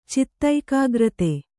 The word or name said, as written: ಚಿತ್ತೈಕಾಗ್ರತೆ